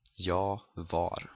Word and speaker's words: jag var: Jag var. And here you actually also drop the R, jag var. Usually.